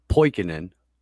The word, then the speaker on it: Poikonen: I'm Steve Poikonen, and it's a Finnish last name, and it's silly. It's not easy to pronounce, and I didn't sign up for it.